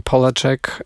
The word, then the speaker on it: Polachek: Będzie trochę piosenek, trochę eksperymentów, będzie bardzo neurotransmisyjnie, a póki co Caroline Polachek, bo tak nazwisko jej się wymawia, dowiedziałem się będąc na jej koncercie na Siget, gdzie graliśmy też my z zespołem.